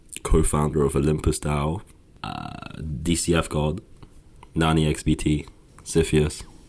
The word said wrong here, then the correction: Sisyphus: Apparently it's pronounced Sisyphus.